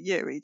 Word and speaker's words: Uri: No, that's U-R-I. – Ah. – But he does pronounce it Yuri, doesn't he, as opposed to Uri.